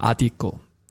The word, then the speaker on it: Adico: Und er hat gesagt, ist die Betonung auf dem A oder auf dem I? Und er hat gesagt, nein, es heißt Adico.